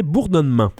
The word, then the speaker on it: Bourdonnement: Je vais le prononcer en français, Bourdonnement.